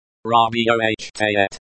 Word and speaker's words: работает: Работает.